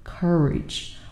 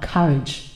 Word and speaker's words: courage: OK，在美音里面，其实我们读的是 courage，而不是大家读的很顺的啊 courage, courage. No. OK，next word.